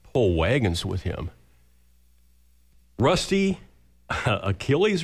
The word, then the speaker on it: Achilles: Okay, Achilles. Or is it a hard C or a soft C? – I guess I make it a K, but... – Okay. Achilles or Achilles, he waits for you, a great big dog.